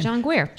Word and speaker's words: Guare: I know if I'm mispronouncing that name. – John Guare. – John Guare. Guar Guir.